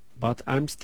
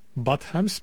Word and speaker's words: Bad Ems: Bad Ems diye mi okunuyor artık, nasıl okunuyorsa kesin çok sever demiş. – Bad Ems mi?